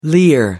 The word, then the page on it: leer: American English